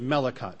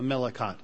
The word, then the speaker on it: Melakhot: In the Orthodox Jewish Melakot, that's spelled M-E-L-A-K-H-O-T, the Melakot,